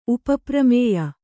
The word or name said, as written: ಉಪಪ್ರಮೇಯ